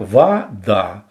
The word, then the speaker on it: вода: Например, слово «вода» произносится как «вода», то есть гласное «о» при ударном слоге редуцируется «ва».